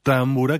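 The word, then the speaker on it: tambura: Y, por ejemplo, la primera támbura, que más bien así lo pronunciarían los húngaros, es la de Aaron Ereditz, que en parte de esta pieza también se ocupa del tambor tapán.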